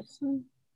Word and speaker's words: Pattinson: Pattinson, Robert Pattinson.